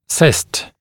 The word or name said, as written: cyst